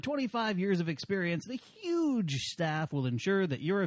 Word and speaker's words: HUG: And, yeah, the show is brought to you by Huge Entertainment. – H-U-G. – H-U-G. It's pronounced huge.